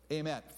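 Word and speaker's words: emet (אמת): Emet, phonetically A-M-E-T, not written that way in Hebrew, emet.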